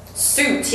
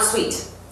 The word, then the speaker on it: suit: Suit, yes. Also careful the pronunciation. Suit is traje, it's not sweet. That's something you eat.